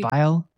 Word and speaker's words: Weil: Weil, I think, maybe. – Weil, yeah.